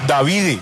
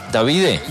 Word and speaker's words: Davide: Yo no sé cómo pronunciar esto, pero se escribe como David con una E al final. Entonces, que fuera como Davide. – Davide.